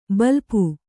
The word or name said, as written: ಬಲ್ಪು